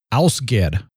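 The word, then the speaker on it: Ásgeir: His name is spelled Asgeir and it's pronounced Ausgeir.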